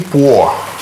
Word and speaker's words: può: Он может «lui può». – «Può». – По. Это читается «о» просто, да? – «По». – «По». По. Точно как пишется, так и читается.